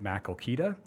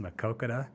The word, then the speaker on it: Maquoketa: And they pronounced it Maquoketa, which, of course, would be the city of Maquoketa in eastern Iowa. Just was through that town about a few weeks ago. I love that place. Played baseball up there a few times. A great, great city. But it is pronounced Maquoketa.